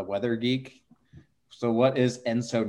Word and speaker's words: ENSO: So can we agree that the proper way to pronounce the El Nino La Nina index is Enso, not Enzo? – Ooh. – I've never heard it pronounced Enso. – I think Enso is right. There's Enzo Ferrari. You may be getting it confused with Enzo Ferrari.